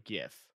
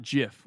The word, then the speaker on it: GIF: So the mispronunciation was so vast that it became GIF. – People knew like that something should be pronounced GIF and not JIF, which is the peanut butter.